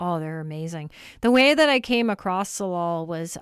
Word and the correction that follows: Tsalal: I was actually no, it's TS. – Ah, okay. – A oh, how do you spell it? TSALAL Salal, and yeah, Gloria, who has this band.